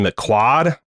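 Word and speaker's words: McQuoid: There might not be any breakouts of this movie, but maybe the possible exception is Simon McQuaid, I think is how you pronounce this guy's name. It looks like McQuaid only with an O.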